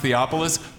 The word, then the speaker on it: Theophilus: and she just pronounced Theophilus perfectly. Yeah.